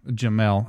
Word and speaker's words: Jamel: One of them is Jamal Hamler. – Jamel. – I always say that wrong, don't I? – It's just like Pat Hill.